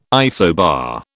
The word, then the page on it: isobar: British English